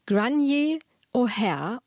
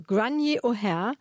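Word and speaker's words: Gráinne O'Hare: Und diese Aussprache, das schreibt sich etwas anders, habe ich direkt bei der Übersetzerin Anna-Nina Kroll erfragt. Die hat mir eine Sprachnachricht bei Instagram geschickt. Also Grainier O'Hare, belegte Aussprache der Autorin, spielt in Belfast.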